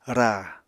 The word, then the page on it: ra: Apatani